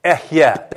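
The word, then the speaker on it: Ehye (אהיה): So here's how you say I am. Ehye. Everybody say ehye. Two syllables, ehye.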